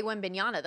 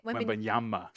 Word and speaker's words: Wembanyama: Am I saying it wrong? – You've been saying it wrong the whole time. – Wembanyama? How do you say it? – Wembanyama. – Shit! I'm going to kill myself. That's one of those things. Okay, Victor Wembanyama. – There you go.